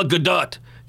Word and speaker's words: Gadot: First of all, it's pronounced Gal Gadot, because she's Israeli, and that's how it's pronounced. She's not French. You're not waiting for Gadot. It's Gadot, but many people get that wrong.